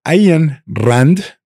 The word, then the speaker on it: Ayn Rand: Anne Rand, eh, Ayn Rand, mejor pronunciado, una filósofa eh, en paz descanse maravillosa, tenía una frase bellísima.